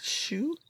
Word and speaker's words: Shue: Elizabeth Shue? Yeah, S-H-U-E.